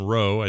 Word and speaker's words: Roux: This is coming this time from author Madeline Rowe, I assume is how you say her last name, R-O-U-X.